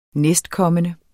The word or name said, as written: næstkommende